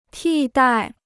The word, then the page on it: 替代: tì dài